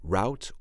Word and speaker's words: route: As I mentioned earlier, R-O-U-T-E can be pronounced route or route. When I was a kid, I had a paper route. So we pronounce the word route, R-O-U-T-E, when we talk about a paper route.